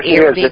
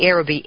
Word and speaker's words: Aerobee: The yes, Arabee it is. It's Arabee. – Arabee, thank you. The Arabee, A-E-R-O-B-E-E, rocket project.